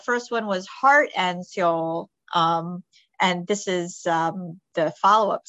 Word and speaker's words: Seoul: Although, am I pronouncing it correctly? – Seoul. – Yes.